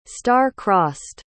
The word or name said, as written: star-crossed